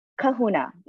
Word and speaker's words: kahuna: You pronounce it Kahuna? – Kahuna, yes.